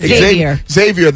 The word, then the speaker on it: Xavier: Is it Xavier, right? – Xavier.